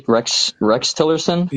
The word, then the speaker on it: Tillerson: And then it was State Department Tillerson, right? Am I saying that right? Rex Tillerson? – Yeah, he was the Secretary of State.